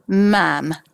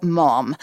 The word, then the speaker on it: ma'am: And then very sensibly, you call her ma'am, but not mom. – Ma'am rhymes with ham.